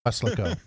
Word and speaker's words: Weslaco: It's called Weslaco. So you park your car at the border control point in Weslaco, and you walk across the Rio Grande Bridge into Mexico, into a town.